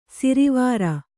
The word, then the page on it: ಶಾರ್ವರ: śarvara